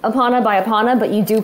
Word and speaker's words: Epona: I say Epona. – I've always said Epona. – In Japanese, it'd be Epona.